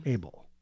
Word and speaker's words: Æbl: to pronounce it would be ending the word with your tongue between your teeth. – Okay. – Abel.